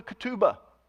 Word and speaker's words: ketubah: It began with what was called the ketubah. Let me pronounce that for you again. Ketubah, K-E-T-U-B-A-H. The ketubah was like a prenuptial agreement.